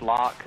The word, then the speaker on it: Locke: Just Locke is spelled L-O-C-K-E, and my last name is Wheeler.